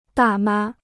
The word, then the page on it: 大妈: dà mā